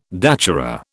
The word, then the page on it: datura: British English